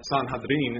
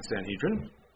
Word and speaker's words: Sanhedrin: And he was on the Jewish Sanhedrin Council, or Sanhedrin is the way it's pronounced, but we say Sanhedrin.